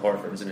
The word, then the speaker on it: Horford: By the way, are you doing a Southern accent or your impersonation of someone from down Georgia way when you pronounce Al Horford's name? Like Horford. – Yeah, Al Hartford. – I keep on saying Hartford. – Yeah, he's not a city in your beloved state of Connecticut.